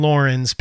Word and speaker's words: Loren: Lauren, yes. His name is Lauren, yep. – Yes, which is interesting. – L-O-R-E-N, not Lauren. – Yes, L-O-R-E-N, yes. So Abby and Lauren's parents die at the beginning of the movie, right?